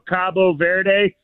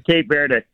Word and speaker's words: Cape Verde: Yeah, they in Spanish it's Cabo Verde, Cabo San Lucas or whatever in Mexico, Cabo Verde. But okay. The English pronunciation is Cape Verde.